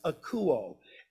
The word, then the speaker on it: akouo: And so hearing in the Greek there means, it's this word, it's pronounced akouo. It's spelled A-K-O-U-O.